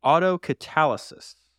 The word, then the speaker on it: autocatalysis: So it's pronounced autocatalysis.